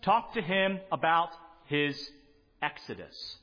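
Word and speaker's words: Exodus (ἔξοδος): Let me pronounce it for you in Greek. Exodus.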